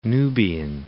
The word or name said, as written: Nubian Desert